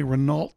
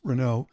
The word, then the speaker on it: Renault: was a what we called a Renault Dauphine. We, of course, mispronounced Renault. It was a Renault Dauphine.